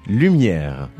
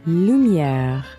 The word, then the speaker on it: lumière: Lumière. – Lumière.